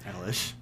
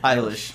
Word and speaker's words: Eilish: Eilish. – How do you say it? – Eilish.